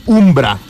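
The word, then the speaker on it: Vmbra: Esta banda se llama Umbra, pero con V, se escribe Umbra, pero la U es V.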